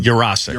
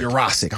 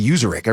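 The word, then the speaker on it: Yurosek: But when you count, if you're a coach watching the All-22 and you count, it is 1-2-3 throw. Justin Jefferson's running a four-step speed out. – Yeah. – He hits him. User, how you say it? Say it again. – Ben. Jurassic. – Jurassic. I want to say Userick every time I say like I just said it. Baby carrot.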